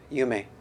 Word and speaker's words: Yume: Instead, he picked up his brush and brushed the character Dream, in Japanese pronounced Yume.